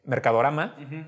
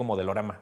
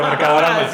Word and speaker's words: Mercadorama: está este Mercadorama. Y lo dije bien porque siempre les digo modelorama. – Siempre, pero Mercadorama.